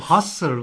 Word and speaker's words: Husserl: Bu Husserl okunuyor.